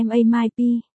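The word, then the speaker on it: ma mai pi (妈卖批): Phát âm là ma mai pi, đây là một từ mắng chửi vùng Tứ Xuyên, mang theo tính vũ nhục rất nặng, dịch thô ra tiếng Việt là.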